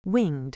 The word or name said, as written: winged